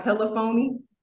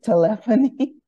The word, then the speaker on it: telephony: It's not telephony. – No, it's telephony. – Telephony. – Okay. I think it's telephony.